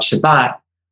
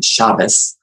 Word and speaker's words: Shabbat: Now, you asked me about Shabbat, the Sabbath, Shabbat, or in Yiddish Ashkenazi pronunciation, Shabbos, Shabbat or Shabbos is the weekly holiday.